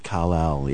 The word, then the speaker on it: Kal-El: Kal-El, yeah.